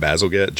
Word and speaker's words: Bazalgette: I guess his name is pronounced Bazalgette, Joseph Bazalgette,